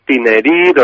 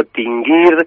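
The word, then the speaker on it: Tinghir: Sí, bueno, es que se escribe de tres o cuatro maneras diferentes y cada vez me lo veo de una, pero es Tinerir o Tinguir. Yo creo que sería así, Tinerir o Tinguir, debe de ser las dos formas así más comunes de pronunciarlo,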